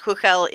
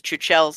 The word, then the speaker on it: Chuchel: Because that's how it's spelled, even though it's pronounced Huchel in Czech. But the devs are on record as having said that they've heard people pronounce it as Chuchel so often now that they've just, like, accepted that as the name.